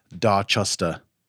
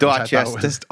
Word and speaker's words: Dorchester: the way that the pronunciation guide on the internet, it sounds like Dorchester. It says D-A-W-C-H.